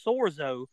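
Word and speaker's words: sozo: And if you look up the word save in the Greek, I believe it's pronounced sorzo.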